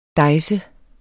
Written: dejse

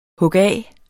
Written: hugaf

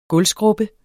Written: gulvskrubbe

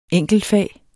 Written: enkeltfag

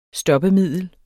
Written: stoppemiddel

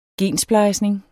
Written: gensplejsning

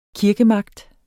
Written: kirkemagt

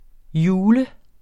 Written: jule

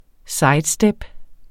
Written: sidestep

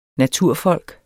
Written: naturfolk

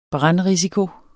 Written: brandrisiko

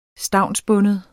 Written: stavnsbunden

